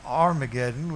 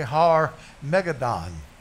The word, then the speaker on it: Har Megiddon: It really isn't Armageddon. That's what we say in the English. The pronunciation is really Har Megiddon.